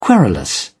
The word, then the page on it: querulous: British English